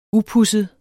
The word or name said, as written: upudset